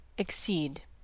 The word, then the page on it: accede: American English